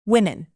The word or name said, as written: women